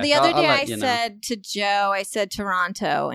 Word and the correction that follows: Toronto: And he was like, it's Toronto.